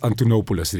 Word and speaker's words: Antonopoulos: Van Andreas Antolopoulos. – We moeten zijn naam correct uitspreken, because he's a legend. – Antolopoulos. – Andreas Antonopoulos, Riva.